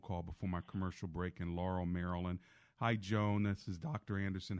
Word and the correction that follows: Yoan: Yoan is the name. – Yoan. – Mispronounced.